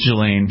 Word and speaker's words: Ghislaine: I heard it pronounced Ghislaine.